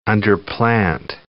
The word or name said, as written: underplant